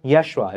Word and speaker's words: Yeshua: Jesus kind of confuses us, but it's pronounced Yeshua.